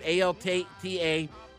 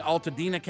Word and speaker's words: Altadena: In Altadena, California. Is that how you pronounce it? Altadena, Altadena, California. – Mm-hmm.